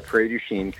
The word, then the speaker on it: Prairie du Chien: And it's in, am I saying this right? Prairie du Chien? – You are. Prairie du Chien.